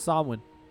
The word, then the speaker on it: Samhain: But remember, Samhain is actually pronounced Samhain.